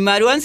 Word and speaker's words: Marwan: Y Marwan se pronuncia así, pero se escribe Marván con doble B. El, tema es que él es de origen palestino, su papá lo es, y entonces se castellaniza así para pronunciarlo.